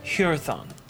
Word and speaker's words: Hyrthon: Hurathon. – It's spelled H-U... – H-Y-R-T-H-O-N.